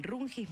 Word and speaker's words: Rungis: visitaron el Rungis Marché Internacional.